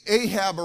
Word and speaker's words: Ahab: And when they did that, Elijah, see, what happened is this king, Ahab or whatever, A-H-B-A-B, huh?